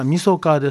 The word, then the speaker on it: 晦日: 味噌化ですね、うん、これはまあ 30 日を指してるんです、味噌化、うん、 30日と書いて味噌、はい、味噌と読みますからね、